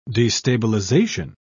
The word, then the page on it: destabilisation: British English